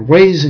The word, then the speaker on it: Vav: Wa for Vav. Remember, it's always pronounced as a W.